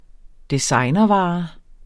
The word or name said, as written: designervare